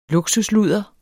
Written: luksusludder

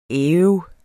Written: æv

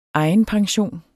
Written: EGENPENSIONEN

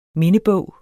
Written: mindebog

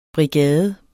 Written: BRIGADEN

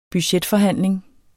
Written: budgetforhandling

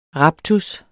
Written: RAPTUSSERNES

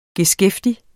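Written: GESKÆFTIG

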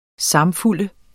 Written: samfulde

